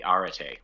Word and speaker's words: Arete: John had the stand and had, at that time, they just had one model called the Arete, which is a tough name to pronounce.